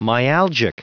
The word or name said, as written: myalgic